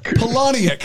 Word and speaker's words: Palahniuk: Am I saying that right? Chuck Palahniuk. – He's the guy on MSNBC. – Palahniuk. – Palahniuk. Thank you, Chuck Palahniuk.